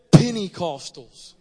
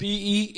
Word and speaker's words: Pentecostal: We are not a bunch of poor, pathetic Pentecostals. I love when people pronounce it that way. We got somebody that preached here several times. Penny. It's not Pennecostal. It's Pentecostal. We're not a bunch of poor Pentecostals.